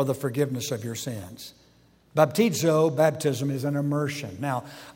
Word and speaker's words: baptizo: The word is baptizo. It's spelled thusly, B-A-P-T-I-Z-O.